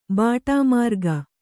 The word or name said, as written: ಬಾಟಾಮಾರ್ಗ